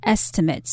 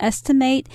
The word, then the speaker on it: estimate: And this word also has a noun form, which is estimate. So it's pronounced slightly differently. The verb is to estimate and the noun is estimate.